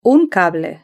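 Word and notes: un cable: Spanish (Spain)

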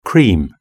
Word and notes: cream: British English

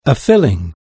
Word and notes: filling: British English

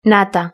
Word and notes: nata: Spain Spanish

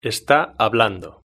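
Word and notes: esta hablando: Spanish (Spain)

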